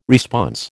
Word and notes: response: American English

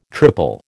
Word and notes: triple: American English

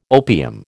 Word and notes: opium: American English

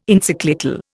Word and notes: Encyclical: Indian

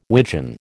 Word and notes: widgeon: American English

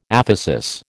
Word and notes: aphesis: American English

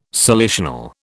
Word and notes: salicional: British English

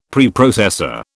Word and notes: Preprocessor: British English

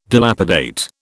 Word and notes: dilapidate: British English

